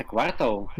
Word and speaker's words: квартал: И вообще ставят на другое, на другой слог ударение, То есть, квартал? то есть говорят квартал. – Угу. – Вот. А вот у нас именно такая особенность, типа квартал.